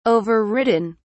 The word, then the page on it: overridden: British English